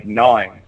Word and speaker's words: gnawing: Like most rodents, their teeth grow continuously throughout its life and must be constantly worn down by gnawing. Sorry. I can't believe I almost said that wrong.